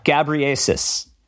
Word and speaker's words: Ghebreyesus: The man experiencing an instant migraine is Dr. Tedros Ghebreyesus. Did I pronounce that right? – Ghebreyesus.